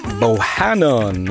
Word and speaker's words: Bohannon: trummisen Bohannon.